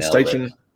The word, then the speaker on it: Steichen: And am I saying that right? I've like never heard Shane Steichen's name pronounced. – Yep. – Is that how you say it? – Look at Steichen. Steichen. Yeah.